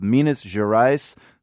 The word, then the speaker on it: Minas Gerais: all came up with Minas Gerais.